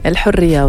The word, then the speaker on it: El Hurriya: El Hurriya, oui, c'est ça, pour la prononciation.